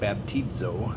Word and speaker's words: baptizo: It actually sounds like baptizo. That's what the Greek word is actually pronounced.